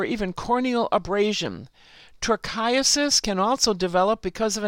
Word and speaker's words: trichiasis: The next, a term I hadn't heard of, trichiasis. I'll spell it for you, T-R-I-C-H-I-A-S-I-S.